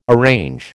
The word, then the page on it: arrange: American English